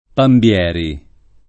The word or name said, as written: Pambieri